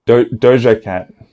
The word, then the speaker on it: Doja Cat: Is that how you say it? Doja Cat? – Doja Cat. – Oh, okay. Doja Cat. Doja. Oh, what is it? Doja or Doja? – Doja. – Doja Cat.